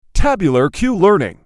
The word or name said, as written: tabular q-learning